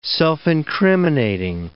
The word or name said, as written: self-incriminating